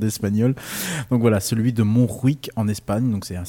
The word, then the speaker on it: Montjuïc: Alors, je ne sais pas si on dit comme ça. Moi, je pense qu'on dit comme ça parce qu'il y a un J. Ça s'écrit M-O-N-T-G-I-U, pardon, I-C. Donc, ça doit être Montjuïc si on la prononce à l'espagnol.